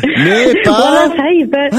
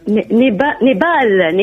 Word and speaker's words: نيبال: خلينا نعرف فيك تنطق يا خيتي اسمع نيبا هاي قاعد حرف برك هاي نيبا نيبال نيبال